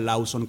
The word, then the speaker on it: Lawson: Recordémosle a la gente que Lauson, se escucha Lauson con U, pero se escribe con W, L-A-W-S-O-N.